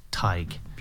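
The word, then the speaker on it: Tadhg: And it's Tig. It's like a tie you wear and put a G at the end, Tig.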